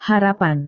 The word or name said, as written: harapan